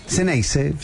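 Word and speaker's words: Zeneize: Y se pronuncia como Ceneice, es una X.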